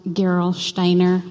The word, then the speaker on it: Gerolsteiner: My German son said it's pronounced Gerolsteiner, but I know I sound more Norwegian than German.